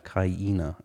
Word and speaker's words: Kaina: Like hyena? – Yeah, Kaina, I think.